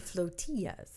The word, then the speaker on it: flotillas: I think you mean flotillas. – Sure. – Like tortillas. – They're floating tortillas is what you're saying? – No, but they're it's pronounced the same.